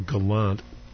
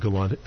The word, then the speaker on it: gallant: Yeah, first of all, gallant could be a noun. They're both taken from French, and gallant is closer to the French pronunciation.